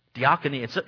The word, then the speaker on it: diakonia: Now, the Greek word used here in this scripture for service is the word diakonia. Diakonia, it's really sad that I put it out phonetically, and it's still hard to say, right? It's D-I-A-K-O-N-I-A, but phonetically it's diakonia, which means ministry.